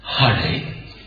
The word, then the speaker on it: Hare: Well, in the Sanskrit mantra, the correct pronunciation is Hare with the ha and Rama.